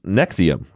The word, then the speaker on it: NXIVM: Is that it's pronounced Nexium, because you sent me, let's talk about NXIVM.